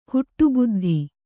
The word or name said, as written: ಹುಟ್ಟುಬುದ್ಧಿ